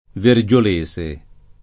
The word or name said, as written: Vergiolese